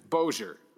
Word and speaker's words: Bossier: Anyway, there will always be some friction between the two schools until either ULL moves to Bossier. – Bossier, right.